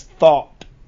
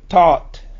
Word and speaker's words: thought: She didn't know how to say the word thought, and I said, that's thought, and she kept saying taught, and I said, no, it's thought, and I'm sitting there thinking, never thought about it in my head how to say the sound.